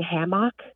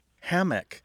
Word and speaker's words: hammock: Hammock tends to have a stress on the first syllable, where hammock tends to have a stress on the second syllable.